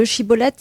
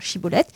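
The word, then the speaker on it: shibboleth: chibolette. Et s'ils le prononçaient avec le si plutôt que chi,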